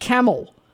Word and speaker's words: Campbell: Well, Campbell is pretty interesting because, of course, it is Campbell, but it's pronounced Camel.